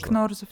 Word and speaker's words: Кнорозов: Да, зовут его Юрий Валентинович Кнорозов. Единственное, что, возможно, я с э, ударением ошибаюсь, потому что он Кнорозов, возможно. Нет, Кнорозов, все правильно.